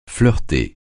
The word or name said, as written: flirter